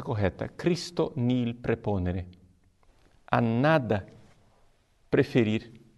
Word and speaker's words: Christo: Ao invés de Cristo, como nós falamos em português, deve se pronunciar Cristo.